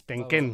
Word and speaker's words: Tenken: Tenken. Tenken. Tenken. Con acento en la... E.